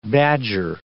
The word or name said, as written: badger